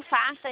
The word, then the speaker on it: Facta: É, o Facta, ele aparece no aplicativo como Facta Financeiro. O C é mudo. F-A-C-T-A.